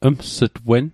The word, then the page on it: ms't_wen: Mi'gmaq